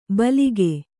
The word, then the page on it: ಭೀಲುಕ: Kannada